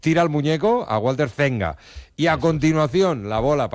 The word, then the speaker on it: Zenga: ¿Cómo se pronuncia Walter Zenga? – Zenga. – Ah, ¿no se pronuncia Seña? – No.